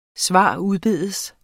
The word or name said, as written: s.u.